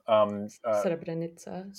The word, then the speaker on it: Srebrenica: Srebrenica.